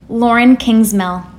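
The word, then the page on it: Lauren Kingsmill: English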